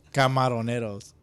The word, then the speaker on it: Camaroneros: How do you pronounce that again? – Camaroneros. – There you go.